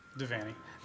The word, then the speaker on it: Devany: Devany.